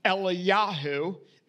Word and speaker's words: Eliyahu: Actually, in Hebrew, it's probably more along the lines of Eliyahu is how Elijah's name would be pronounced.